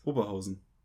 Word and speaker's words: Oberhausen: Oberhausen. Ja. Das ist Betonung auf Ober und nicht Oberhausen.